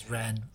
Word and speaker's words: Rahne: The five here, so Maisie Williams play, I always pronounce it as Rahne. It's Rahne. R-A-H-N-E.